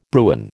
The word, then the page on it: Bruin: American English